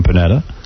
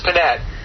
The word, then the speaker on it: Panetta: Hi, Howard, it's Panetta. – Oh, sorry. – Whatever. – You gave him an extra syllable. – Oh, I see, Panetta. Because he's got the E at the end. It's Panetta. Panetta.